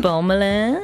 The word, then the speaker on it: Parmelin: Parmelin.